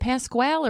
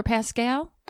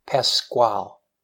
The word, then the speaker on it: Pasquale: Is it Pasquale or Pascal? – Pasquale. – Pasquale. – Pasquale. Ooh.